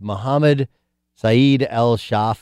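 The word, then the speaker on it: Mohammed Saeed al-Sahhaf: Okay, it's Mohammed Saeed al-Shaf, I believe is how you pronounce his name.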